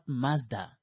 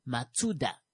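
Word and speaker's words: Matsuda (マツダ): It turns out it's not Mazda. It's Matsuda. I was like, I don't have time for that.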